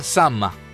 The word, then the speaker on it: Samhain: Y los druidas, nada más y nada menos, eran satanistas que alababan y servían al dios de la muerte, y se llama Samhain, el dios de la muerte, pero que se pronuncia Sama.